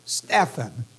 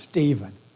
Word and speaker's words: Stephan: Dave does not like this name to be pronounced Stephen. Unfortunately, in some parts of the country, when I looked it up, they do say Stephen. Phonically, Dave is right. There is no V in here. But you know how English is. Names can be pronounced differently. But we will pronounce him Stephan.